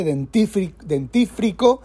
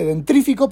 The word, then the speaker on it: dentífrico: Mucha gente dice dentífrico, dice dentrífico porque es mucho más fácil de pronunciar.